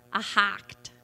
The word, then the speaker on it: echad: Ahad. E-C-H-A-D.